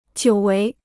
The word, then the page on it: 久违: jiǔ wéi